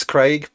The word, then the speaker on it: Craig: The first name, it's Craig, but that's the English pronunciation.